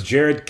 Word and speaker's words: Jered: Yeah, my name is Jared King, spelled the exact same way. Not King with Russo, but Jared and Jared spelled the exact same way, J-E-R-E-D.